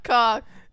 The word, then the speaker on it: caulk: C-A-U-L-K. Easy peasy. Easy peasy. – L is silent. I told them, I was like, there's no H because you're thinking about chalk, not cock.